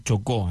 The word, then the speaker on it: Chocó: Es el Chocó, así se pronuncia.